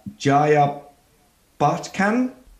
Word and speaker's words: Jaya Bachchan: Uh, Jaya Bachchan. Jaya Bachchan.